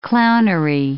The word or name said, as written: clownery